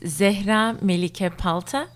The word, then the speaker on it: Zehra Melike Palta: Okay, so I'll say my name again in the Turkish pronunciation. It's Zehra Melike Palta, and I'm a third-year PhD student in the Language and Literacies Education Program.